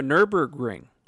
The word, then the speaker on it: Nürburgring: The Model S is back at the Nürburgring.